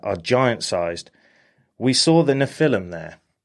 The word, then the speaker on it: Nephilim: I think that's how you say it. Nephilim? – Nephilim, yeah.